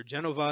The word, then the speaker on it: Genova: Genova is the Italian pronunciation. Genoa is the American word.